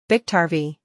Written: Biktarvy

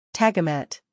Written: Tagamet

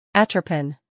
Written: Atropen

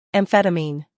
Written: amphetamine

